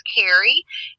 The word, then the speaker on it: Carey: My first name is Carrie.